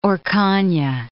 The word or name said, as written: Orcagna